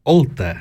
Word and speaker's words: Olten: Und nein, es ist nicht Biel, sondern es ist Olten. Olten, Olten, e O-L-T-E-N.